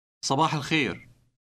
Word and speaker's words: صباح الخير: The last part contains a sound similar to the last sound in the composer's name, Bach. – الخير. الخير. صباح. صباح. صباح الخير.